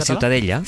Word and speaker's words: Ciutadella: Ciutadella.